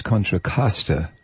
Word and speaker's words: Contra Costa: Mike, you're using the correct Spanish pronunciation, but the way we say it is Contra Costa County. The way you say Costco or Cost Plus.